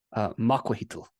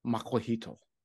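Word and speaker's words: macuahuitl: And how do you pronounce that again? – Makuahitl. – Makuahitl. Makuahitl. Makuahitl. Okay.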